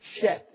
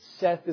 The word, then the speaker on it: Seth: Sheth is the term in the Hebrew. Seth is the way we pronounce it.